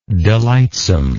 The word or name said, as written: delightsomeness